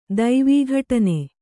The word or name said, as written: ದೈವೀಘಟನೆ